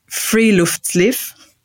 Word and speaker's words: friluftsliv: And now I've got to say this right because it's not German. Apparently it's Norwegian. So it's Friluftsliv, which is the concept of a fresh air life or living outdoors.